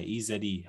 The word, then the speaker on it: Eze: EZE.